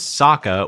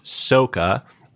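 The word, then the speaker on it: Sokka: Not only did he mispronounce that, the character played by Jackson Rathbone, whose name in the cartoon is Saka, was pronounced Soka.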